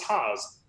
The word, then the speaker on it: Haase: It rhymes with pass. That was the first time he, because I called him Haase, because my oldest brother played at college, and he had a teammate named Marty Haase, and so I thought it was Haase, and he goes, oh, it's Haase, rhymes with pass.